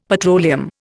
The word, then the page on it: petroleum: Indian